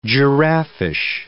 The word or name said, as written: giraffish